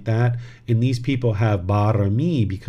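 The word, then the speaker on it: barami (บารมี): It's pronounced barami.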